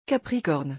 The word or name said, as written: capricorne